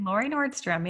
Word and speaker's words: Laurie Nordstrom: Laurie Nordstrom, yes. In the U.S., I always say Nordstrom, just like the store, because we have a huge department store named Nordstrom's.